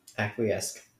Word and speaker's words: Acquiesce: How do you say it? – Acquiesce. – Okay.